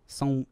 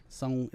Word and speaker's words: São: São, it's got the A-O with the little squiggly. São, it's very nasally.